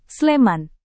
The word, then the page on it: sleman: Indonesian